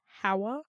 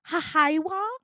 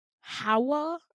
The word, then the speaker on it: Hawa: And I go, Hawa. And she goes, Hahaiwa. And I go, bitch, it's 2 syllables. It's not that difficult. Hawa. And she goes, Hawaii.